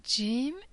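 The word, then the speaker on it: Jim (جيم): Jim is pronounced like J in jug or jewels.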